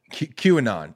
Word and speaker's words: QAnon: How do you say it? It's QAnon. Yeah.